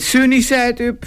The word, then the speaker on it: Sunny Side Up: Het Sunny Side Up Festival, zeg ik het goed? Nou, ik weet het niet, hoor. – Ja, helemaal goed.